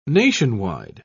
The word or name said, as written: nationwide